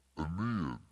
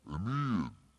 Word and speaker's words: Aeneid: Aeneid. There you go. Elida. I think of Elida, New Mexico. Aeneid.